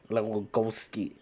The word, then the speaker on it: Lewandowski: Yeah, Lewandowski.